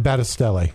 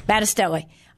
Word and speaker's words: Battistelli: Battistelli. – Battistelli.